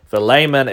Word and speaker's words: Philemon: I've heard Philemon, Philemon, Philemon. Philemon is the technical way to pronounce it.